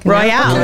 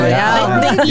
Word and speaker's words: Royale: Did I say that right, or is it just Casino Royale? – No, Royale. Royale.